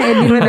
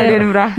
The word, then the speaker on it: Edinburgh: Benar ya bacanya Edinburgh. ya. Edinburgh. Edinburgh.